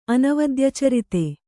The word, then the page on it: ಅನವದ್ಯಚರಿತೆ: anavadyacharite